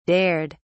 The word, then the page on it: dared: British English